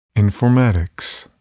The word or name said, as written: informatics